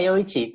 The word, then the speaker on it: Yoichi: and am I pronouncing it right? – Yeah, Yoichi, that's fine. Yoichi is good.